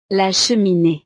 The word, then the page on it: la cheminee: frances